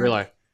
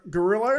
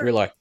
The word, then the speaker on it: Grillo: Grillo. – Gorillo? – Grillo.